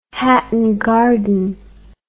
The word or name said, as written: Hatton Garden